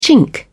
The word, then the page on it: chink: British English